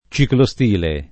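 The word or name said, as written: ciclostile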